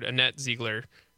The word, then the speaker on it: Annette Ziegler: I'm always going to leave space for me to be wrong, but I'm pretty sure I've heard Annette Ziegler as the pronunciation for Wisconsin's Chief Justice.